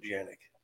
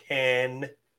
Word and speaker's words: Canik: And we did a little video for Toby because Toby calls it Janik. And he said Toby's it is weird. – Pronounced Can-ick.